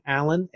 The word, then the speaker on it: Allan: The other way, which is easier, is go to LinkedIn and type in my name, Allan, A-L-L-A-N. Last name Sang, it's like a silent T, T-S-A-N-G.